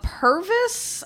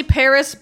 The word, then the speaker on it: PVRIS: Purvis, I guess. I don't know. Paris. – Purvis. – Pervert. – It's probably Paris, but it's spelled P-V-R-I-S.